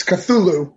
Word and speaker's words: Cthulhu: It's Cthulhu is how I pronounce it.